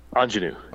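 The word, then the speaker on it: Aunjanue: Best Supporting Actress, Jessie Buckley, Ariana DeBose, Judi Dench, Kristen Dunst, and I cannot pronounce this, Anjouan Ellis? – Ingenue. – Ingenue, yeah. Ellis. – Yeah, interesting way to spell ingenue.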